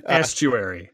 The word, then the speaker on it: estuary: And they also mentioned that it was the planet is, I'm going to pronounce this wrong, Esoteri? Is that right? – An estuary.